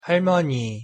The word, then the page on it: 할머니: Korean